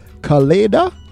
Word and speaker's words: Kaleida: K-A-L-E-I-D-A.